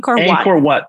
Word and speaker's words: Angkor Wat: So I went to the temples of Angkor Wat. Is that how you say it? How do you say it? – Angkor Wat.